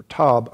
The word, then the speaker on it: Tob: Then Jephthah fled from his brethren and dwelt in the land of Tob, or Tob. I'm saying Tob because that's the way it would, the Hebrew pronunciation would be.